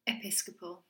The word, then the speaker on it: Episcopal: Oh my God, how do you say that word? – Episcopal.